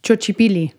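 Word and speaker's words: Xochipilli: And I think I would pronounce it Chochipili. How would you pronounce that? – Chochipili. – Chochipili. – Mm-hmm.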